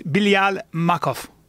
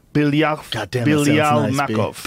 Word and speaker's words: Bilyal Makhov: Bilyal Makhov. – Is that how you say it? How do you say that? – I would say it's Bilyal Makhov. – Bilyal Makhov.